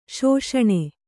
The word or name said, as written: ಶಾಸನ